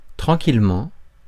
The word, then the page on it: tranquillement: [tʁɑ̃.kil.mɑ̃]; France